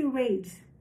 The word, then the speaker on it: Reid: Wallace Price tornou-se Wallace Reid. Aqui está falando embaixo, trocadilho com a palavra reed, que tem uma pronúncia similar a reid e é o nome dado a plantas altas e finas, como cana, cana e bambu.